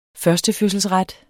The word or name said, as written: førstefødselsret